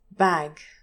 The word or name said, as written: bag